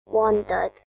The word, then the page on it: wandered: British English